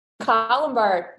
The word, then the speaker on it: Colombard: I don't even know how to say the word. Colombard? – Colombard. You got it.